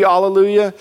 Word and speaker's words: alleluia: Another theory is that it was alleluia for all of time, because the actual way you would pronounce it in Hebrew would be alleluia,